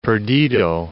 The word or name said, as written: Perdido